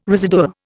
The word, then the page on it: residua: Indian